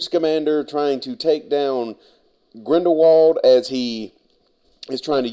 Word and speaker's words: Grindelwald: And I realize we've all been mispronouncing his name, as in the movie they constantly call him Grindelwald.